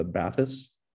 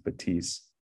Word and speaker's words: Batisse: And I think a lot of North Americans are going to just read the spelling and think it's the Bathys, the B-A-T-H-Y-S. But the French pronunciation is Batisse.